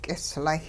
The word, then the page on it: k'islay: hupa